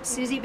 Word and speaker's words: Suzie: Well, I'm on Facebook, Suzie Bell for Arkansas, spelled with a Z, S U Z I E, Susanna.